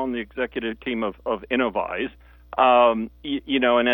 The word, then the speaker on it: Innovyze: So, a couple of people going, what's Innovyze? Right? Is that how you say it, first of all? Is it Innovyze? – Innovyze, yes, sir. That's the correct pronunciation.